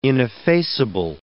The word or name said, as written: ineffaceable